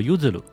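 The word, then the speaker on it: 結弦: 不过，因为他的名字里边这个结弦的日文发音叫 Uzuru，和日文里边这个吃的柚子的发音是非常像哈，所以不少中国的粉丝都直接喊他叫柚子。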